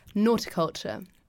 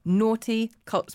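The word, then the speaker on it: Noughticulture: Nauticulture. – Naughty culture. Spell it. – N-O-U-G-H-T-I culture. Like horticulture with an N.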